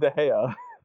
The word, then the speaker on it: De Gea: Right, isn't it DeGaia? – It's David De Gea.